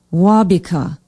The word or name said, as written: waubeka